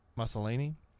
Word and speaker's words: Maslany: Tatiana Maslany, is that how you say it? – Sounds good. – Maslany.